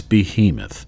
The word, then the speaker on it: behemoth: Wait, hold on. Behe. – Yeah, Moth. – Yeah, behemoth. – Okay, it's behemoth.